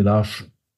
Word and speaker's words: Lars: Nee, Larsch. Larsch.